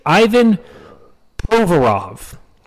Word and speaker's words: Ivan Provorov: His name is Ivan Provorov.